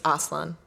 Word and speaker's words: Aslan: Although I always pronounce it wrong because in Turkish it's Aslan.